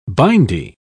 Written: bindi

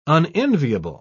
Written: unenviable

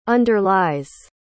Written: underlies

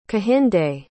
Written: kehinde

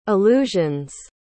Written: allusion's

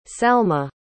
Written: salma

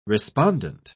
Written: respondent

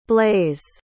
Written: blaize

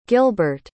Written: guilbert

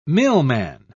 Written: millman

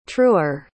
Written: truer

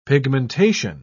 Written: pigmentation

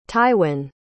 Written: tywin

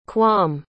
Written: kwam

